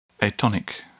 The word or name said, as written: atonic